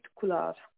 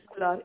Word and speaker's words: Kular: My full name is Manpreet Kular. – How do you spell that? – Its last name is Kular.